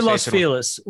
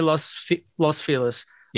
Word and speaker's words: Los Feliz: How do you say Los Feliz? I say Los Feliz. Yeah. Most people up here say Los Feliz.